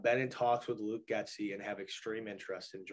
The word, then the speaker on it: Getsy: I always say Gatsby whenever I say his name because of the book Great Gatsby, read that in English class back in the day. Luke Getsy. That was Davante Adams' favorite coach,